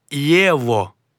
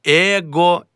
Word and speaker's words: его: В інтелігентній мові вимовляють «єво», а не «его» і не «ехо».